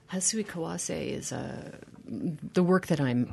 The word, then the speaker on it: Hasui Kawase: Is that pronounced correctly? – Yes, Hasui Kawase.